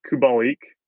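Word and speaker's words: Kubalík: Because the other guy that I have been riding pretty hard the last 5 or 6 games is Kubalik. That's the pronunciation.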